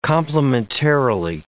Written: complementarily

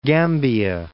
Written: Gambia

